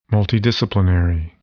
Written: multidisciplinary

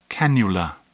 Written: cannula

